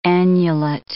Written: annulet

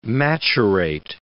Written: maturate

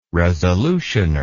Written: resolutioner

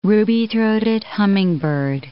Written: ruby-throated hummingbird

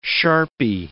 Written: sharpie

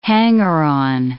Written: hanger-on

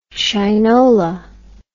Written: shinola

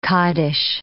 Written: kaddish

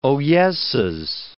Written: oyesses

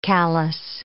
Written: callous

